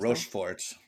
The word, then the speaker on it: Rochefort: Richfort. Did I say that right? – Like Rochefort.